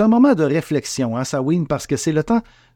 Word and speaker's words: Samhain: Vous voyez, je me J'essaie de dire Sawin, mais j'en reviens toujours à ne C'est pas pourquoi. Ben, peut-être parce que ça s'écrit S-A-M-H-A-I-N, Sam, Ain. Bon, bref, euh, ça ne concerne que moi, alors mais on va dire Sawin, OK?